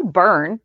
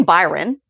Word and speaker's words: Byrne: So in Harmony's 411, I kept mispronouncing Neil's last name. It's Neil Byrne. I kept saying Byron.